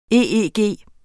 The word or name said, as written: EEG